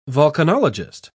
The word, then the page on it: volcanologist: British English